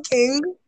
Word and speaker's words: Qing: Once again, my name is King. You can find me at King_herself on Instagram. King is spelled Q-I-N-G.